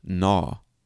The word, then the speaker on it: gnaw: Another example is the letter G in the word gnaw. Okay? G-N-A-W. Gnaw. The G is silent.